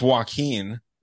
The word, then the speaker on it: Joaquin: How'd you say his name? – Joaquin. – Okay. – Yeah. How does he say it? I haven't even taken notice. – I mean, it's Joaquin. That's how you say that in the States.